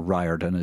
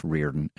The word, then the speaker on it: Riordan: Rick Riordan is the man's name, or Riordan as you may be pronouncing it. It's pronounced Riordan. It's an Irish name.